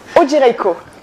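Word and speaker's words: Odzierejko: c'est très simple, ça se prononce comme ça s'écrit. Alors O-D-Z-I-E-R-E-J-K-O, e e donc Odzirejko.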